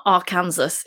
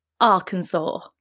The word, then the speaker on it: Arkansas: I can help you with that because I always struggle with it because I pronounce it as I read it, and yeah, it's not pronounced like that. It's not Arkansas, it's Arkansas.